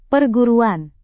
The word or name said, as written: perguruan